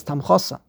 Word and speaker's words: Tamchosa: You don't know how to pronounce it. It's not Tamcha, it's Tamchosa.